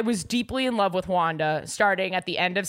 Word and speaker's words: Juana: J-U-A-N-A. – Wanda. – But pronounced Wanda.